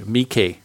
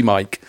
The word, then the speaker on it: Miike: I'd have probably called him Takeshi Mike if I'd not have heard Chris pronouncing his name on the podcast a few times,